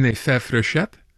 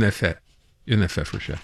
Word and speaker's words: fête frechette: How would I say that, Alice? The frechette effect in French is une fête frechette. – Yeah, that's it. – Une fête frechette.